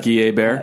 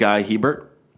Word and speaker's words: Hebert: Like Guy A-Bear? – He is my favorite. – Do you know that Guy A-Bear is American and his real name is actually pronounced Guy Hebert? – No.